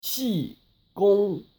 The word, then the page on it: Qigong: Aussprache